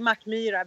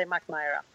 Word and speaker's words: Mackmyra: It's like that's the logic of reading it out in English. So I just say Mackmyra because that's how we pronounce it. But it's completely fine if you say Mackmyra.